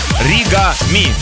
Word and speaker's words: Оригами: Это трек называется Ори. Нет, неправильно начал. Оригами. Так лучше расставить ударение. Оригами.